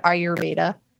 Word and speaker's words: Ayurveda: Hadley, can you tell us a little bit about the spirituality of, I can't even say the word, Ayurveda? – Yes, you got it.